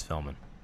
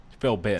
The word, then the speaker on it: Philbin: Regis Philbin. – Philbin. – Philbin. – Yeah, Philbin. Philbin. – Yeah. You can see it in your head, but you're just saying it wrong.